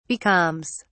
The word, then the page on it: becalms: British English